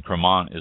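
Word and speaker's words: Crémant: then check out the ones that say Cremant, C-R-E with the little mark above the C-R-E-M-A-N-T, pronounced Cremant.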